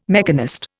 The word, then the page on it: mechanist: Indian